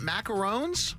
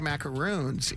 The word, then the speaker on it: macaron: Macarons? – Macaron, like Ron. Like macaron. – Name Ron. – Yeah, see, I've always said, I've always pronounced it macaroons. It's macarons.